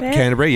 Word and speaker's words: Canterbury: Yep, Canterbury, yep, and New South Wales.